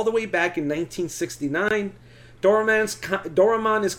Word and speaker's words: Doraemon: Doraemon? – Doraemon.